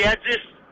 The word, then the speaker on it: Adis: Moi, c'est Adis. – Adis? – Ouais. – Adis. – Comment ça s'écrit, Adis? – A-D-I-S.